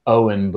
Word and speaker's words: Eoin: But yeah, the way I describe it is Eoin, but the E is silent, so spelled E-O-I-N.